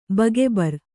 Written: ಬಗೆಬರ್

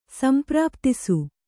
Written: ಸಂಪ್ರಾಪ್ತಿಸು

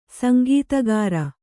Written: ಸುಂಕಾಧಿಕಾರಿ

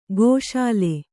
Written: ಗೋಶಾಲೆ

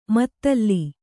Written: ಮುತ್ತಲು

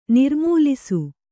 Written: ನಿರ್ಮೂಲಿಸು

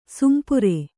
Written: ಸೊಂಪೇರು

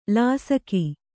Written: ಲೇಸಿಗ

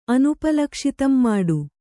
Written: ಅನುಪಲಕ್ಷಿತಂಮಾಡು

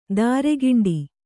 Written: ತರಿಗೆಂಡ

